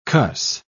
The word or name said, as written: cuss